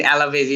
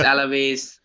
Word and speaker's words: Alaves: We have more worries against Alves, teams like Alves and... I mean, Alaves, it's not Alves. I mean, I mispronounce that a lot. It's Alaves or Elche or Osasuna.